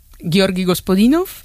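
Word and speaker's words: Georgi Gospodinov (Господинов): Ayúdanos a pronunciar los autores. – Georgi Gospodinov, sí. Zdravka Eftimova y Vera Mutavcheva.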